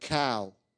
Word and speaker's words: Cao: You know, my last name is spelled C-A-O and pronunciation like cow, okay?